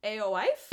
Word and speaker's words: Aoife: And Aoife, her name is spelled A-O-I-F-E. A-O-Aoife. A-O-Wife? – Is that an Irish name? – Yeah, Gaelic. Aoife.